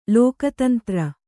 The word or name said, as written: ಲೋಕತಂತ್ರ